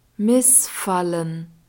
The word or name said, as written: Missfallen